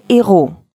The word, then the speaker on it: héros: «Les amis», «des amis», но есть такие слова, начинающиеся на непроизносимую букву «h», с которыми связку делать вообще запрещено. Например, «ce sont les héros» – это герои. Если сделаем связку, француз услышит совершенно другую фразу.